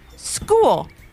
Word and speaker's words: School: School. S-C-H-O-O-L, said Maddie. That's a good one, because it's a trick word. The C-H makes a K sound, so a lot of people spell it S-K-O-O-L.